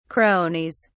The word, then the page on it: cronies: British English